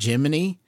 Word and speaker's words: Jiminy: The lamp, the name of the cricket is spelled like Gemini, but he pronounces it Jiminy.